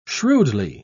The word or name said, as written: shrewdly